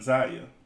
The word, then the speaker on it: Zaya: Zaya. Yes, I said that correctly. Dwayne Wade's son, Zaya, saying that he is a role model for women.